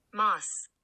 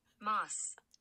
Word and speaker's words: Maas: There's two A's. But then she came out with her, like, promo and was like, hi, this is Sarah J. Maas. And I had a conniption because I've been saying it wrong this entire time, as have all of us.